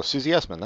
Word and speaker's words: Susie Essman: But did I get her name wrong? – No, Susie Essman, that's right.